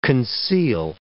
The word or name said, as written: conceal